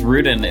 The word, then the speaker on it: Rudin: Very, yes, it's Rudin.